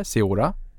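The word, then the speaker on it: Ceora: och på tal om professionella aktörer, i slutet på 2020 introducerade ni, hur uttalar jag det här, Seora? – Mm. – perfekt. Plötsligt händer det.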